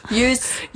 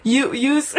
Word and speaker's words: Youths: Youths. – Youths.